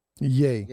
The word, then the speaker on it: Ye: Now, we have to remember it's what's he called? Ye now? – Yay. Yay, yay. Is that's what that's how it's pronounced. Yay.